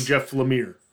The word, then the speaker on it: Lemire: we went out and I'd seen two different competing ways to pronounce the name of the guy whose name is Lemire. And we'd pronounced it Lemire. I'd pronounced it Lemire during the last one. Went out and actually found some audio of him saying his name, and it's evidently Jeff Lemire.